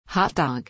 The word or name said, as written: hot dog